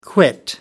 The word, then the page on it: quit: American English